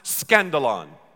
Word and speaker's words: skandalon: In the Greek, it's pronounced skandalon, the stumbling block.